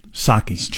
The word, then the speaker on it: Psaki: How do you say her name? – Psaki. Jen Psaki.